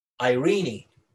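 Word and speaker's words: Irene (εἰρήνη): It's very interesting because the word peace there is a Greek word which actually reads Irene. It's spelled E-I-R-E-N-E, Irene. And the root word of the word Irene is Iro. It's E-I-R-O.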